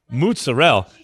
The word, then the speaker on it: mozzarella: She goes, no, that's not how you say it in Italy. It's mozzarella, like mozzarella. – Everyone thinks. Yeah. Yes. – Mozzarella. – Yes, that's true. That's an Americanism, apparently.